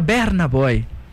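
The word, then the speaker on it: Burna Boy: É, o Berna Boy, que se pronuncia Berna Boy.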